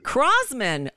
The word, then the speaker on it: Croasmun: Matt, is it Croas? How do I say your last name? – Crosman. – Oh, so I tried to make it so complicated.